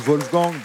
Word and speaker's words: Wolfgang: Oui, Wolfgang, on prononce Wolfgang, parce que c'était de langue allemande, mais c'est bon.